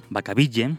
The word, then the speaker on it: Vacaville: En el, en el centro médico de Vacaville, Vacaville, para los que no pronunciáis inglés, acaba de cumplir 74 castañas, este 18 de diciembre, uno de los asesinos más infames de los que ha habido allí alojados.